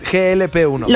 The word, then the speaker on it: GLP-1: GLP-1.